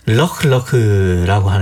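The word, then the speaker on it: Lheureux: 럭럭이 발음은 이제 앞에 관사엘이 축약돼서 럭럭이로 네. 발음되는데 원래는 어흐예요, 어흐.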